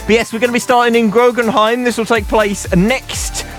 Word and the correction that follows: Groningen: Groningen? – Yeah, that sounds better.